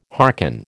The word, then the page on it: harken: American English